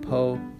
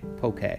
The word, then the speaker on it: poke: And by the way, it's spelled poke, but it said poke. Like, okay, poke.